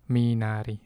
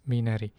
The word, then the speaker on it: Minari: Farming, in this movie, in Minari, or I don't know how they pronounce it really. Minari? No, it's Minari.